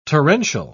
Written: torrential

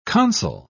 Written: consul

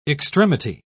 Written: extremity

